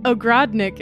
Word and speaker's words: Ogrodnik: So, Ogrodnik is the correct way to say it, then, yeah? – Yeah.